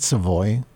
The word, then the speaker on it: Savoy: I think I've been saying it wrong all these years. Isn't it Savoy? Accent on the voy? – Oh, Savoy, yeah, right?